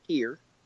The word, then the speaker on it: Pierre: All the locals lit me up about how to say it's pier. Okay, like a fishing pier. It's pronounced like pier. It's not Pierre, but it's spelled Pierre.